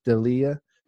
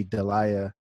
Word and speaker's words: D'Elia: You know, the comedian Chris D'Elia. I might be saying his name wrong. It might be Delia or D'Elia. – I think it's D'Elia.